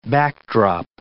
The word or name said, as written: backdrop